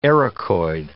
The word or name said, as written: ericoid